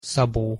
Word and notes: szabo: Hungarian

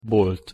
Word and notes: bolt: Hungarian

